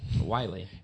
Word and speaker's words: Wile: How do you say Wiley Coyote's name? – Wiley. – It's spelled the same way. W-I-L-E Coyote.